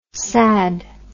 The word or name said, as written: sadd